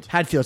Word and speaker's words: Hadfield: Chris Haddonfeld? – Hadfield, sorry.